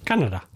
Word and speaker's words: Kaneda: K-A-N-E-D-A. – Yeah. – Canada. – But pronounced Canada.